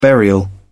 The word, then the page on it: burial: British English